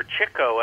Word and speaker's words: Chico: However, Chico, and that's the correct pronunciation of his, pronunciation of his name because he huh.